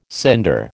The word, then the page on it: cinder: American English